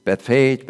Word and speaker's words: Bethphage: Haley Lee, who read the scripture, she nailed the pronunciation of Bethphage. It's Bethphage, Bethphage, Bethphage. Greek is probably close to Bethphage.